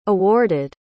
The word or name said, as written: awarded